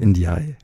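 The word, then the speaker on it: NDiaye: Ja, danke für die schöne Lesung aus Y penser sans cesse, unablässig daran denken von Marie Ndai. Habe ich das richtig ausgesprochen? – Indiai. Indiai. Ja.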